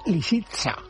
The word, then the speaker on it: Lisitsa: lo he dicho bien. – Lo has dicho fatal. – A ver cómo es. – Pues hombre, Lisitsa. – Lisitsa, con S, no, has dicho otra cosa completamente distinta.